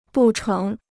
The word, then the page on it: 不成: bù chéng